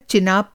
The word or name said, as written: चेनाब